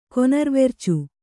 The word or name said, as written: ಕೊನರ್ವೆರ್ಚು